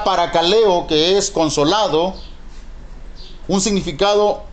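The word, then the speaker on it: paracaleo (παρακαλέω): Quiere decir la palabra consolado en su original griego se pronuncia paracaleo.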